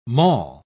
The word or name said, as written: maul